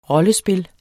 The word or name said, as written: rollespil